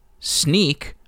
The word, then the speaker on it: Snyk: Snyk, or S N Y K, if you have not known how to pronounce that word, is a company that builds security tools for companies that are consuming open source.